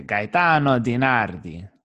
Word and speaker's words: Gaetano DiNardi: If we were in Naples right now, you would say Gaetano DiNardi.